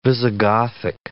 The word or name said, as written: Visigothic